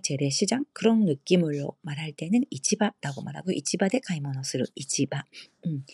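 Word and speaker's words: 이치바라고 말하고 (市場): 이치바라고 발음하고요 똑같은 한자지만 주식시장 시조, 가부시키 시조, 가부시키 시조 이렇게 어, 읽습니다. 같은 한자라도 시조하고 이치바 두 가지 읽는 방법이 있다는 점 기억 꼭 하시고요.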